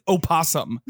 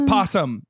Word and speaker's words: opossum: He graduated from UT, and yet he insists that people pronounce it opossum. Are you fucking kidding me? Yeah. The word's possum. You drop the O.